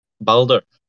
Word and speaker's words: Baldur: It's probably closest to the Norwegian like pronunciation of it. Baldur. – Mm-hmm. – So it's Baldo, was it? – Baldur. So it's like a U and an R that's rolled together.